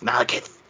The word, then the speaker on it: Malekith: We are very excited to welcome into the MCU Christopher Eccleston as the all-time great villain Malekith, the Dark Elf. – Malekith, man. – I'm pronouncing it the way that our great thespian Odin pronounces it, which is Malekith.